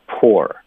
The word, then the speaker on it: poor: P-O-U-R, 就是泼水，我们就叫 poor。然后就是贫穷是吗，就 P-O-O-R, 它的发音也就是 poor, it's the same thing, 没有区别，词典会标有一点不一样的什么的，不用想那么多，就是一样的， it's the same thing。